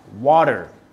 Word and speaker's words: water: Cuando, para nosotros, cuando tenemos un sonido T o una letra T rodeado por vocales o también un doble T, lo pronunciamos como una D en dog, ¿ya? Como en water.